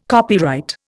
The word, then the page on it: copyright: Indian